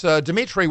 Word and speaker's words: Dimitri: It's Dimitri with three I's, D-I-M-I-T-R-I.